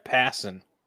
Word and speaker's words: Passan: So Jeff Passon, who like passing a basketball, that's how you say his name, in case you didn't know. Not Passan.